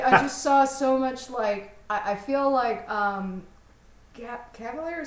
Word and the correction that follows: Cavill: is that how you say his name? – Cavill.